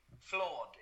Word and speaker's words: FLAWD: And the brand's name is FLAWD, Flawed. It's pronounced the same as flawed in English.